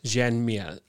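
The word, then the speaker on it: Joan Mir: Jan Mir. Oh, it's spelled Joan, so like in English, like James is James kind of thing.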